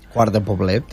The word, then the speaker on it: Quart de Poblet: El domingo a las 7:30, en Quart de Poblet, no sé si lo he dicho bien. – Quart de Poblet.